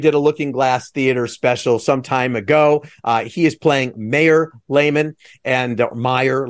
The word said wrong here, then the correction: Mayer: Let's see, you do say it's Mayor, right? – Meyer. – It is Meyer.